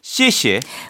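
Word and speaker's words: 씨에씨에 (谢谢): 씨에씨에. – 맞습니다. 근데 좀 더 강하게 씨에씨에.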